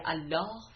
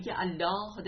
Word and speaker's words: الله: زیرا کلمه الله در متن آیات به گونه ای تلفظ می شود که حرف الف و ه تقریبا تلفظی ندارد و گویی اسم ذات خداوند که جامع همه اسماء دیگر است فقط یک لی مشدد است و گویی لی خود به تنهایی حرف ذات و لفظ ذات خداست و لام باطن کلمت الله است.